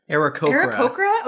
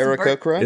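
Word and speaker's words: Aarakocra: And I'm playing, you know what, guys, you gave me I'm playing an Akokra. How do you say that? – Arakokra. – Oh, that's Arakokra.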